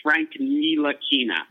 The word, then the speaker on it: Ntilikina: Frank, I've heard it pronounced multiple different – I'm pretty sure it's Frank Ntilikina. – Ntilikina. – I've heard Frank Ntilikina. I've heard Frank Ntilikina. I heard Fran Fraschilla during the NBA draft combine say Frank Ntilikina, and he would know.